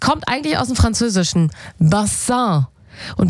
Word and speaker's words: Bassin: Und naja, die Aussprache, die hat ein bisschen gehapert. Also statt Bassin wurde es irgendwann Baseng.